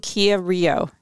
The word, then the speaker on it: Kia Rio: I love my 2015 Kia Rio.